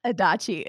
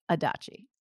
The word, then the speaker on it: Adachi: Adachi. It's totally fine. Like hibachi. Rhymes with hibachi. Adachi.